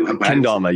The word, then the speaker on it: Kendama: Kendama, yeah.